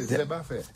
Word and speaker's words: zébafer: Et en créole, c'est zébafer. Zeb, comme herbe, affaire. – Zéba. – Parce qu'il est chargé en fer aussi. – Ah, Zeb à fer. – Ouais. On prononce pas les R ici.